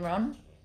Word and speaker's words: Rohn: Well, it's spelt like Ron, but anyway. – Okay. Rohn. Isn't it R-H-O-N? – R-O-H-N.